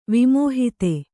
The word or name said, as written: ವಿಮೋಹಿತೆ